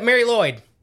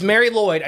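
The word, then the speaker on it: Mari Lwyd: Yeah, Mary Lloyd. It's Mary Lloyd. I know it's not spelled Lloyd. – Right. Okay. – But it is Mary Lloyd.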